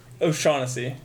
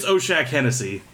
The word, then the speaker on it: O'Shaughnessy: The name is Corey's tight end, James O'Shaughnessy. – No, it's O'Shack Hennessy. If you take a hard G. Okay. It's O'Shaughnessy.